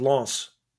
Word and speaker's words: Lens: How was my pronunciation of Lens? – Pretty damn good.